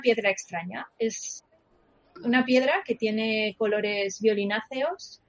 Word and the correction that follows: violáceos: violáceos, ¿cómo se dice? – Bueno, violáceos, ¿no? – Violáceos.